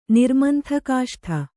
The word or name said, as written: ನಿರ್ಮಂಥಕಾಷ್ಠ